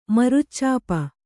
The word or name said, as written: ಮರುಚ್ಚಾಪ